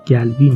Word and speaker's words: قلبي: موقعی که شروع میکنه متوجه میشید که یا قلبی رو یا گلبی میگه عربای لبنان قاف رو گه تلفظ میکنن